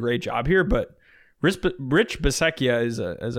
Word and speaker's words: Bisaccia: How do you say his name? Bisaccia? The Raiders intern right now. – I think it's Bisaccia, I think.